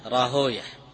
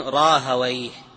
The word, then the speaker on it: راهويه: هكذا المحدثون ينطقونها راهوية لأنها هكذا سمعت، المحدثون ينطقون الأسماء كما سمعت لا يغيرون فيها لأنه لا قياس في الأسماء، ولكن النحويون يقيسون يعني عليها فيقولون راهويه، النحويون يقولون راهويه،